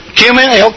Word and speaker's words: cumin: I want some, how do you pronounce that? C-U-M-M-I-N. Cumin, okay, it's a thing in the Middle East there.